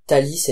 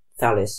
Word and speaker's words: Thalys: So we pronounce it Thalys in French, but in English it's thallus, so you pronounce it right.